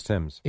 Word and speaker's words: Thames: I remember when he first came up, and I'm like, all right, somebody's mispronouncing this name. – Right. – But, yeah, no, it's Marcus Timms.